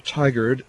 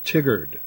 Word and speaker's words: Tigard: But they need to learn to pronounce the city of license correctly, Tigard, not Tigard.